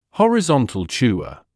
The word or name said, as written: horizontal chewer